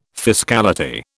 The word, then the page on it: fiscality: British English